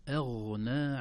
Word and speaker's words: أغنى: إذن أيضا 4 حركات، لكن كلمة أغنى، أغنى عنهم، الهمزة مرققة والغين مفخمة، لا نقول ما أغنى أغنى، لا، ما أغنى أغنى، نرقق الهمزة ثم ننتقل إلى الغين مفخمة أيضا لينة في النطق.